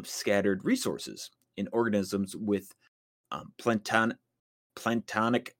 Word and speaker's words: planktonic: It's plankton. I was saying it wrong. – Planktonic. – It is planktonic eggs or larva, but it's, I thought it meant something else, but no, it's just plankton. Another